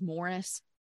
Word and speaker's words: Maurice: I will say that Morris is spelled like Maurice. That's how I would say it. But in interviews with Dan Aykroyd and his dad, they say it is Morris.